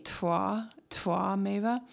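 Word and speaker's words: Tvameva: I just really like the word Twameva, and it's spelled, I believe, T-V-A, but it's kind of a combination between a W and a V, Twameva.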